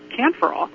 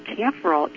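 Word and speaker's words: kaempferol: The other compound in calendula flowers that we have not yet talked about is camphorol.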